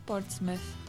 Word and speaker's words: Portsmouth: No Portsmouth.